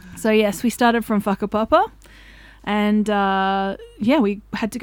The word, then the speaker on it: Whakapapa: I'd like to preface this with a WH in Māori is an F phonetically, so it is called Whakapapa, which I didn't know until halfway through the hike and someone had told me.